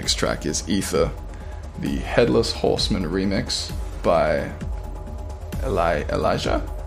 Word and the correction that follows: Aleja: Aleja, maybe. – Aleja. – Aleja Sanchez. Aleja. If that is a Spanish name and the J is pronounced as a H.